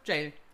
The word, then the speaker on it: Gaol: Aber das ist tatsächlich das altbritische Wort oder die alte britische Schreibweise von Jail. Ähm, es heißt nichts anderes und es bedeutet auch nichts anderes und es wird genauso ausgesprochen.